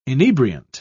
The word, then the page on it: inebriant: British English